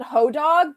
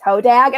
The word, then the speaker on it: Hodag: I always pronounce it Hodog, but like my in-laws are from the Midwest and they're like, you're pronouncing it wrong. It's Hodag.